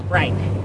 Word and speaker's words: Frank: I'm Reverend Frank Wolf, and the last name is spelled W-U-L-F as in Frank. – And your first name also? – Frank. – Frank. And that's F-A... – F-R-A-N-K.